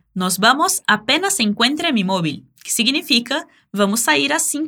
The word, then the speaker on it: móvil: Móvil. Essa palavra é uma forma mais simples de dizer telefone móvil. Perceba que o V tem som de B e o L no final é bem pronunciado.